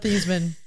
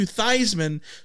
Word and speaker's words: Theismann: His name is Joe Theismann. – Changed his name to Theismann so it would rhyme with the Heisman for PR purposes.